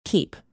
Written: keep

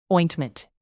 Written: ointment

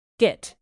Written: git

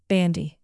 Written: bandy

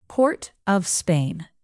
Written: port-of-spain

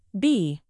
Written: bee

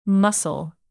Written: mussel

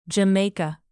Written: jamaica

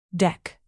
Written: deck

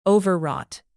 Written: overwrought

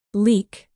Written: leek

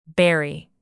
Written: bury

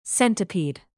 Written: centipede

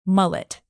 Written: mullet

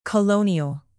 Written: colonial

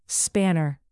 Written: spanner

